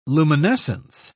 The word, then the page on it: luminescence: British English